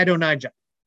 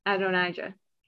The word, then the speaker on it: Adonijah: How do you say his name? Adonijah. – Adonijah.